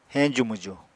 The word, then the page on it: heñju-mwju: Apatani